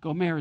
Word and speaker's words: Gomer: Gomer is how you say it in Hebrew, Gomer.